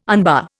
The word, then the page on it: unbar: Indian